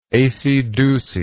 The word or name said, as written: aceydeucy